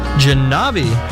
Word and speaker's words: Jahnavi: Janavi. – How do you spell that? – J-A-H-N-A-V-I.